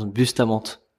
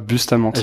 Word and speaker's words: Bustamante: Comment ton nom, on le prononce comment pour être sûr ah, Mon nom, ça se prononce Bustamante. Tu vois, Bustamante. J'ai francisé mon nom de famille. Plutôt que j'arrive et que je dise je suis avec Nicolas Bustamante.